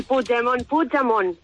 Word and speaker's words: Puigdemont: ¿Cómo está Puigdemont? A ver si me aprendo a pronunciar el apellido. – El Puigdemont. Muy bien, no lo pronunciáis mal, está perfecto.